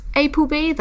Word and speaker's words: APLB: So this is another brand new release from the brand APLB, which is pronounced ApoB. That's how they like you to say it in Korean.